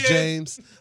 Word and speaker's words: James: Yeah, my name is James Andre Jefferson Jr. Again, you can find me on Twitter, Instagram, Snapchat, at James Jefferson J.